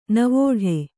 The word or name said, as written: ನಾವಾಟ